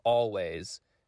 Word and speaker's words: Alvvays: And that is the Canadian group called Always, and it's spelled A-L-V-V-A-Y-S. So the W is actually two Vs.